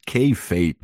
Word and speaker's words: kayfabe: So so the the name of the the video essay is kayfabe.